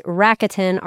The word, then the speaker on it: Rakuten: There's another one called Rakuten that I pronounced improperly for like, I don't know, 6 years, but it's Rakuten, R-A-K-U-T-E-N.